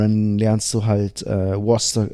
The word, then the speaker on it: Worcester: Aber Worcester, einfach W-U-S-T-E-R. So sprechen die das aus.